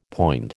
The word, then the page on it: poind: American English